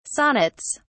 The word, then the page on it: sonnets: British English